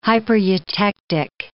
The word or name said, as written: hypereutectic